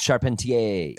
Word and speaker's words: Charpentier: It's actually pronounced Charpentier.